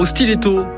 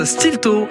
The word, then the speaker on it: stiletto: Alors vous, comment vous dites ? Stilto ou stiletto ? Plus de questions à se poser, on prononce stilto et c'est faux que tu m'aimes.